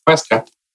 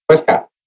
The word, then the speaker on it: Huesca: and Barcelona did have a big win against Huesca. – Huesca. – I know, you said it right.